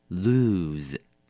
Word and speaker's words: lose: 第一个字 lose, 动词输或者失去，要小心咯，它念成 lose。